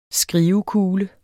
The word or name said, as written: skrivekugle